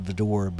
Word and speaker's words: Widor: Well, the first one is, the artist's name is Charles Vidor, and it's spelled, that's W-I-D-O-R. I think it's pronounced Vidor, but it could be Widor.